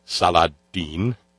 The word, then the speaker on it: Saladin: Also true, although generally I think we redneck it as Saladin. Saladin. But he's Saladin. Rhymes with Bin Laden, see? – Though the Crusaders failed...